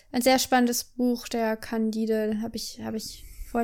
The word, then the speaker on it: Candide: Candide? Candide, so hätte ich es auch aussprechen können gerade noch. Der so. Candide, so wie Candida, nur ohne R hinten.